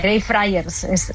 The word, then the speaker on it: Greyfriars: Así se pronuncia, Greyfriars.